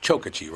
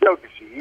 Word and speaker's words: Chokachi: Actually, it's pronounced Chokachi, right? – Chokachi. You got it.